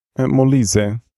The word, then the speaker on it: Molise: Molise.